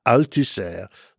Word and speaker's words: Althusser: Althusser. Luis, the Luis is spelled Louis, Luis mm-hmm. Althusser.